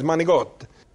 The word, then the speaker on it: manicotti: It's not manicotti. It's manigote.